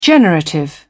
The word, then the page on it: generative: British English